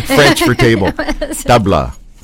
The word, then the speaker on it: tabla: But for people who don't know that much about Indian music, they're mesmerized by our percussion instrument, which is tabla. And I didn't pronounce that right, did I? – Tabla? I think you did.